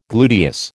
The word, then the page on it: gluteus: American English